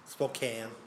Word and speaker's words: Spokane: Yeah, you know, I mean, I think you've got Spokane, too. – Yeah. – Is it Spokane? – Yeah. Spokane, yeah.